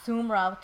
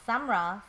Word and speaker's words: Samra: I've got Sumra too, and I don't know where the Sumra comes from, because there's no U in my name at all. It's pronounced Samra, so sometimes I say, you know, it's like the sum of two numbers, and Ra.